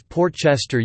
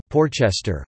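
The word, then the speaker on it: Port Chester: When the train's voice announced that the next stop was Portchester using two words instead of Porchester, we knew, that was a machine. It could not know that we New Yorkers pronounced this place as one word, not two.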